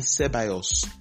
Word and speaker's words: Ceballos: It's Dani Ceballos, not Dani Ceballos.